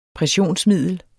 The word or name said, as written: pressionsmiddel